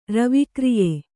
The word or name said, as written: ರವಿಕ್ರಿಯೆ